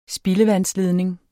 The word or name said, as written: spildevandsledning